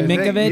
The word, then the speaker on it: Minkowicz: Did I pronounce that right, Minkowicz? – Yeah.